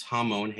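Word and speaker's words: Hamon (המון): Well, in Hebrew, it's Hamon, Hey Mem Vav Nun.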